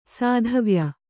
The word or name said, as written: ಸಾಧವ್ಯ